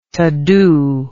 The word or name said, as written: todo